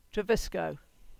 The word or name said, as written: Treviscoe